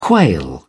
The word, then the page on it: quail: British English